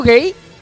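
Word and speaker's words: Guéhi: Genera un poco de confusión porque se escribe Güeji. – Ah. – Pero ya explicó él que la pronunciación es gay.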